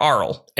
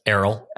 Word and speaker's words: Errol: Is it Errol or Arl? – Errol. – Okay, good. I said that right. Like the bird and the owl in Harry Potter. – Yes, but spelled much spelled differently. – That's like Errol Flynn.